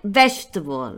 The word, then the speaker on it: vegetable: Fijaos dónde pongo el acento, en la primera sílaba, como en vegetable, no es vegetable, vegetable, comfortable, shackable, fuckable acento en la primera sílaba.